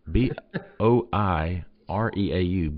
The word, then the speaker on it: Boireau: Then they've got some 2024 guys like Caleb Holmes and Michael Boireau. Oh, I think I said that right. – B O I R E A U Boireau.